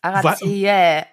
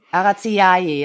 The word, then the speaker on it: Araceae: Also das AE am Ende wird, glaube ich, Ä gesprochen. Das ist nur, was mir gesagt wurde. Ja, richtig, genau. Du kannst es auch AE aussprechen. Araziae, ja, das geht natürlich auch.